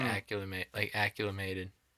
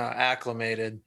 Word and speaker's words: acclimated: We had like a 6-pack of beer, but in those kind of situations, you need like shots to get acclimated. – Mm-hmm. You need to start it. – Acclimated? – No, acclimated. – Yeah. – You're adding an extra fucking syllable in there.